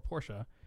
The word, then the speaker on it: Porsche: But another kind of fun thing is we all know the story of Porsche, or Porsche, as the correct pronunciation is, wasn't able to get their 2026 bid.